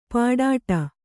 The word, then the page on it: ಪಾಡಾಟ: kannada; pāḍāṭa